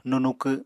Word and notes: nunukw: Apatani

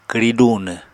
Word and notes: kwri dunw: Apatani

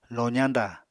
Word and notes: lozañdà: Apatani

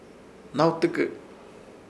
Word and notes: Hnawtɨkɨ: Ishir; Chamacoco

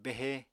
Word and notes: behe: Apatani

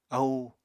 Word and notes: ahú: Apatani

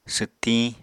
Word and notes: swttiñ: apatani

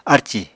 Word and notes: arcì: Apatani

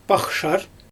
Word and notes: paxshar: Xyzyl

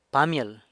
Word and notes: Pamyël: sanlucasquiavini

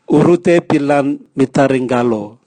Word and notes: urute bilan mitari ngalo: Raga

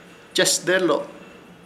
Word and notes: Chesdelo: Ishir; Chamacoco

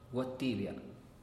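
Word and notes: guatibia: Teotitlán del Valle Zapotec

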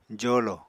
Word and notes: jolo: apatani

